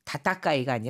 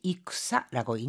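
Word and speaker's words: 익사라고 (戦): 같이 다타카이가 아니라 같이 익사라고 읽는다는 거 기억해 두시기 바랍니다.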